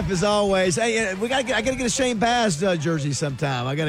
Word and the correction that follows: Baz: You know, I hate to tell you this, but he does pronounce it Boz. – Oh, boo! – Yeah, well, thanks for telling me that. – He says it. He spells it the same way.